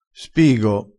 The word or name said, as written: spigo